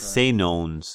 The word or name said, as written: Senones